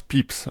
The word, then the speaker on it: Pepys: Samuel Pepys, qui se prononce Pepys en réalité, mais ça s'écrit P-E-P-Y-S,